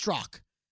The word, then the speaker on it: Strzok: It's pronounced Strzok, but it's spelled S-T-R-Z-O-K.